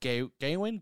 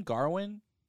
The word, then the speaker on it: Gawain: It's this tale of Gawain, Garwin. There's a few different. It's debatable on how you pronounce the name.